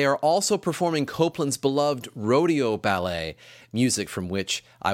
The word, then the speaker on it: Rodeo: As I said in our season preview episode, you may have heard the title of this ballet pronounced Rodeo. It was fashionable at one time to use the French pronunciation of this title, and it in fact still is acceptable. I'm going to stick with the American rodeo.